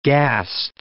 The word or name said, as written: ghast